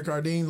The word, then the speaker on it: Cardin: Did I say his name wrong? Cardin. – There you go. – Cardin. – Yes.